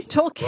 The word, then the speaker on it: Torquay: It's spelt talk on my screen, like motor talk. – Talk, but I thought, oh, it might be Torquay, but okay.